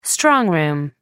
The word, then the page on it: strong room: American English